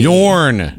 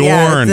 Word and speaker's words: Jorn: Jorn. It's Norwegian. – Yeah. Oh, Jorn. – Yeah. The J is silent.